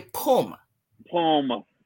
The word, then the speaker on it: pum pum: So i got to teach you how to say it. Pum. Say pum. Pum. Pum pum.